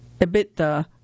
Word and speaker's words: EBITDA: The acronym EBITDA, pronounced like a word, rolls off Paltrow's tongue, along with a glossary of other biz-side jargon.